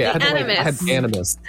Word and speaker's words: Animus: Okay, Animus. The Animus. It's like Animaniacs.